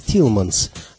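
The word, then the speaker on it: Thielemans: которого весь мир знает под именем Тутс Телеманс. На самом деле, его полное имя Жан-Батист Фредерик Исидор Телеманс. В Америке его фамилию произносят как Тилманс, а Тутс – это прозвище, которое молодому музыканту дали приятели в послевоенной Бельгии, чтобы его имя звучало как-то более джазово.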